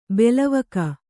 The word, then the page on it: ಬೆಲವಕ: belavaka